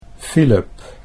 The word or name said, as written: Philip